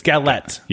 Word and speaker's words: galette: Galette. It's Galette. – Yeah. I figured, we probably had the wrong emphasis on some of the vowels. – Wrong syllable. Yeah. Yeah, no. No, it's Galette.